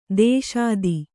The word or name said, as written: ಧಾಷತ್ತು